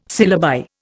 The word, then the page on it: syllabi: Indian